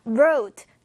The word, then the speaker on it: wrote: 자, wrote, W에 너무 집착하지 마시고 R 발음이 난다고 생각하면서 해주세요.